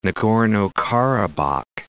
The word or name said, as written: Nagorno-Karabakh